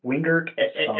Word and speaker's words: Wingerston: It's Wingerston. – Wingerston. – Everybody likes to take the S and the T, and they do the little swap on it. So I don't get too offended, because I've been dealing with it for a long time.